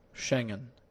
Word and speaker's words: Schengen: Perdón, digo, esta palabra es complicada de pronunciar, pero es Schengen, algo así, Schengen. ¿Qué facilita? Sí, más o menos así, Schengen. No, pero yo conozco cubanos que dicen, no, la visa es Schengen. No, no es Schengen, es Schengen.